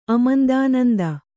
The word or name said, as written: ಅಮಂದಾನಂದ